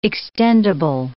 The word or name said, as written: extendible